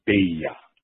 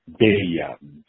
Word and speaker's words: Beyer: When we first moved to Japan, because our name is spelled B-E-Y-E-R, we were told by Japanese that were there that it's B-E-Y-E-R, so you have to pronounce your name in Japanese as Beya. Beya. B-E. Y-A-A, Beya, B-E-I-Y-A-A, Beya.